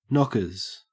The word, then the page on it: knockers: Australian English